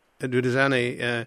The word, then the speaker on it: Duduzane: And it looks as though Zuma's son, Duduzane, is that how you pronounce his name? – Yes, that's right. – Duduzane, he's kind of the bag man for the father, and mm-hmm.